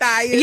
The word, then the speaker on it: Matthias: How do you say this? – Matthias. – Yeah, Matthias.